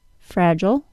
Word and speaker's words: fragile: The American schwa pronunciation, ul, and the British long I pronunciation, I'll. Fragile, fragile.